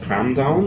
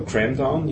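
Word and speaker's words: kramdown: Und der heißt Cramdown oder Cramdown, je nachdem, ob man es Englisch aussprechen will oder nicht.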